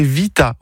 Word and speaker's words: vita: Non, c'est en espagnol que le V se prononce presque comme un B. Non, en italien c'est Vita.